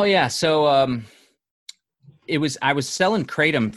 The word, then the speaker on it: kratom: Did I say that right? Cradium? – Kratom. – Kratom.